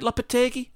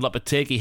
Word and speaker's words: Lopetegui: Have I said that right, Lopetegui? That sounds right in my head. – Let's go with it, yeah.